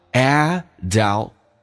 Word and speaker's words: adulthood: adulthood 要记住这个单词重音是在 a 上有点难叫 adulthood 那个 t 可以不用发音